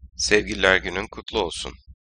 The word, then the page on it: Sevgililer Günü: Turkish